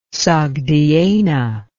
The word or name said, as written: Sogdiana